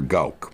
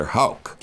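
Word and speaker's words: Gauk: In German, the G's are pronounced H's and the H's are pronounced G's. So it's Vladimir Gorovitz, we say Horowitz, and Alexander Gauck, it's spelled, but it's really Alexander Hauck.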